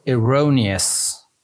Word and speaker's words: erroneous: The word was erroneous. Erroneous. The stress is over the second syllable.